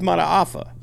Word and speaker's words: Mataafa: You know, he played – yeah, Hercules Mataafa. – Yeah, you actually said it right.